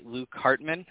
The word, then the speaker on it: Luke Hartman: If you haven't already heard, Starks won Gen Con 2022 Nationals, and it was piloted by Luke Hartman.